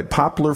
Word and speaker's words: poplar: Where is your popular forest. I didn't say that right, did I? Poplar forest.